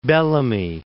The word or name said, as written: Bellamy